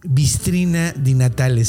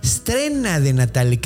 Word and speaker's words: Strena di Natale: No se dice Bistrina di Natale, se dice Strena di Natale, que está todavía más cercano.